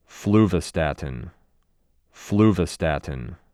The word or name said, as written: Fluvastatine